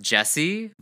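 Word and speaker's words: Djesse: I believe it's pronounced Jesse, Volume 3 by Jacob Collier.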